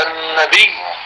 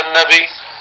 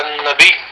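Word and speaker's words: النبيّ: آية الكرسي، كمان اللفظ الأخير الكرسي، مثل لما نقول النبي، ما نقول النبي، لا يقال إن الله وملائكته يصلون على النبي، مش على النبي، على النبي. هذا أكثر الخطباء في الجمعة لما يقرأوا هذه الآية ما بيقولوا اللفظ الصحيح، ما بيقولوا على النبي، بالنطق بالياء المشددة.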